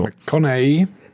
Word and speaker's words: McConaughey: McConaughey. – Connie, ach, der wird Connie ausgesprochen.